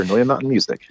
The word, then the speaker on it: Noya Mountain Music: Yes, sir, Noya Mountain Music.